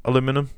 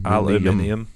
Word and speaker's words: aluminium: You're not pronouncing it right. – Yeah, I know. It's literally missing letters. – It's aim. Aluminium.